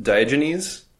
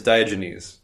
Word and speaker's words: Diogenes: Next up, I think I mispronounced Diogenes. It's Diogenes. I don't know what I said. – I think it's Diogenes.